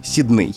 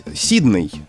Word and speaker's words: Сидней: Это название австралийского города Сидней, которое, согласно словарям и в том числе словарю трудностей для работников СМИ, СМИ Михаила Абрамовича Штудинера произносится в образцовой речи именно так – Сидней. Но есть э, ряд людей, и это очень популярная тенденция э, говорить Сидней. Ну, потому что так э, говорят же в оригинале Сидней, в английском говорят Сидней.